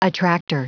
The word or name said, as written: attractor